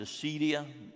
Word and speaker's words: acedia: Sloth, known by another term, acedia. From an old word, Acadia. I pronounce it acedia.